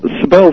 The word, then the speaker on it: Sybil: Well, Sybil is how you pronounce her name.